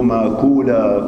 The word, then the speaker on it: ماكولا: أبو نصر هذا ابن ماكولا كما نطقت به، احفظوا ماكولا خطأ، ماكولا.